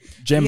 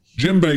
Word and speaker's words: djembe: I'd like to put djembe. – Djembe. Yeah. – Djembe, you spell that D-J-E-M-B-E.